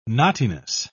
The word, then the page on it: knottiness: British English